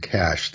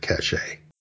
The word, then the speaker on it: cache: So I think the only error that really creeps in here is when people are trying to use the word cache, they'll pronounce it cache.